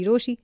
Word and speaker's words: Rochie: मेरा नाम है मिर्ची रोशी, नाम की स्पेलिंग ROCHIE.